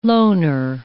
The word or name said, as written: loner